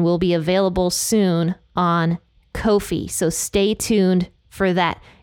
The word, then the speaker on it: Ko-fi: I just found out that it's pronounced Ko-fi, and I'm very sad about that.